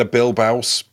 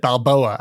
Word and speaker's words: Bilbao: That's not how pronounced that earlier, so that's going to make the edit interesting. If that's the word was saying, because I think you said Balboa, as in Rocky.